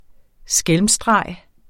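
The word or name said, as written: SKÆLMSSTREG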